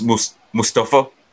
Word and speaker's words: Mustafa: Guys, you mispronouncing it. – Mustafa. – It's Mustafa. Mustafa. The way they're pronouncing it on WWE now.